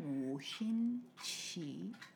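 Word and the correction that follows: 五禽戏: Wu. Wu. Qin. – Qin. – Xi. – Wu qin xi.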